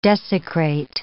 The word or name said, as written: desecrate